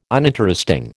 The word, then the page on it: uninteresting: American English